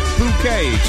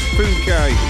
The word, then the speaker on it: Phuket: in Phuket, Thailand. I think that's how you say it. And let's not read it out the way it's spelt. We've not long started, have we? So, I think it's Phuket.